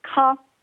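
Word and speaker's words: Caen: You pronounce it ka, kind of like K-A-H, ka. – Ka? – Oh, okay. Ka, okay. – That's just how it's pronounced, versus Khan, which is the one in the south where the film festival is.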